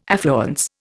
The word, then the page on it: affluence: Indian